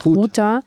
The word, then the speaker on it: Ghouta: Ghouta.